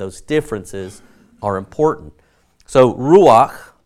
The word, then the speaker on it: ruach: So ruach is what we get from these three.